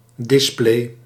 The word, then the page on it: display: /ˈdɪspleː/